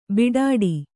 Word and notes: ಬೀಡಾಡಿ: Kannada

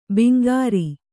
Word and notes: ಬಂಗಾರ: kannada

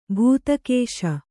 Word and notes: ಭೂತಕೇಶ: bhūta kēśa

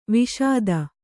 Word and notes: ವಾಶಿತ: Kannada

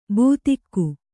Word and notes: ಬೂತಿಕ್ಕು: būtikku

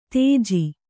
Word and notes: ತೇಜಿ: Kannada

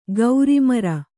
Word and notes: ಗೌರಿಮರ: gauri mara

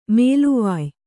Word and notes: ಮೇಲೆವಾಯ್: Kannada; mēlevāy

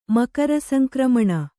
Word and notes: ಮಕರಸಂಕ್ರಮಣ: makara sankramaṇa

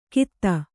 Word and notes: ಕಿತ: kita

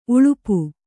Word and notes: ಉಳುಪು: uḷupu

